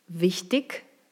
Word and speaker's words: wichtig: Im Hochdeutschen spricht man IG wie ich. Also aus wichtig wird wichtig, richtig, König, ständig.